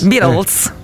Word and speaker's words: Beatles: Beatles. – Non si dico Rolling Stones. – Ma no, ma non credo. Non All'inizio credo. Si chiamavano Pooh, poi hanno cambiato. L'immagine dei Beatles che attraversano la strada la celeberrima copertina dell'album Abbey Road non è stata scattata a Londra, ma si trova a Corso Sempio.